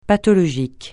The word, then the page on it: pathologique: francais